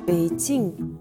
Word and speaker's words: Beijing: D'ailleurs, le nom de la ville, Pékin, se prononce plutôt Beijing et ça s'écrit B-E-I-J-I-N-G.